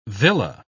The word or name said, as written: villa